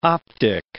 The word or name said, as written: optic